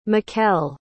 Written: mikal

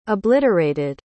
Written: obliterated

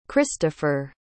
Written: christofer

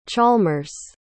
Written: chalmers

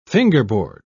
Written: fingerboard

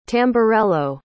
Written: tamburello

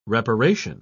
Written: reparation

